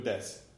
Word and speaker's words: desu: Right, des, and it's D-E-S-U, but you don't pronounce the U.